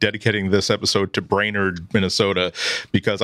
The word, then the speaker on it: Brainerd: This is the Material Podcast, episode number 218, or 218, as some would say, is the area code in northern Minnesota, including Brainerd, which I'm assuming is how you pronounce that word. – Yes.